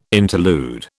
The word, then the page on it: interlude: British English